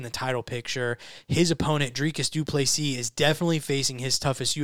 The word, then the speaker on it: Dricus du Plessis: So, the next fight that we are going to pick for, number 5, Derek Brunson versus number 10, Dricus du Plessis. Yes, the S is silent, remember. Derek Brunson comes into this fight off a second round knockout loss to Jared Cannonier 1 year ago.